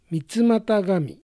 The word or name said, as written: Mitsumatagami